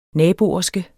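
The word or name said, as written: naboby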